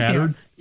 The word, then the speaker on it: Tattered: I think Tattered. – They are. – Sorry, smattered. – Yeah. – Rhymes with battered.